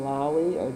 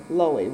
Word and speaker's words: Loewy: Now, did you have, and I mispronounced his name the other time, Raymond Lowy? – Lowy.